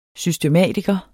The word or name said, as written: systematiker